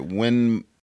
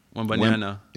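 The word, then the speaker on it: Wembanyama: Wimbanyama. – Wimbanyama.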